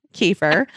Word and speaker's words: kefir: Kefir.